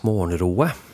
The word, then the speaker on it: Morgenrøde: Ja, de skrives Morgenrode, eller det kan uttales Morgenroe.